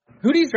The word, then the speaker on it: Houthis: The Houthis are doing something, or Houthis, am I pronouncing it right? So Houthis or Houthis? – Hootie's right. I think hard, Hootie's, it's got the H, but I believe it's the hard T.